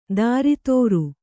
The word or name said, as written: ದಾರಿತೋರು